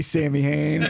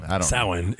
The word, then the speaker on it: Samhain: Samhain is, I guess, the technical pronunciation. – Yeah, Irish. – I'm American, and we do everything like phonetically and stupid.